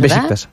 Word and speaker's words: Beşiktaş: Pues tú lo has oído tú en algún sitio, porque, claro, luego de cómo se escribe a cómo se lee, pues es echarle imaginación. – Hombre, se escribe Beşiktaş. – Bueno, Beşiktaş. Bien,